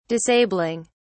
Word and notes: disabling: British English

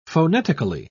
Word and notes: phonetically: British English

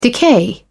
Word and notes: decay: British English

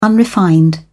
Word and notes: unrefined: British English

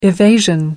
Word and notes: evasion: American English

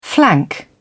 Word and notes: flank: British English

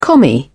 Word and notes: commie: British English

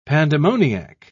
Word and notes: pandemoniac: British English

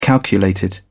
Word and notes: calculated: British English

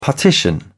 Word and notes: partition: British English

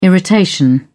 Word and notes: irritation: British English

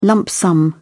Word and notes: lump sum: British English

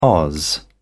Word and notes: oz: British English

